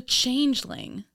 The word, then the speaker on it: changeling: It's the Changeling.